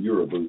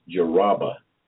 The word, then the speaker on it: Yoruba: I've been pronouncing it Yoruba, but one of my peers informed me that it was Yoruba folks over there in Nigeria.